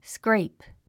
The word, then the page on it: scrape: skréip; スクレイプ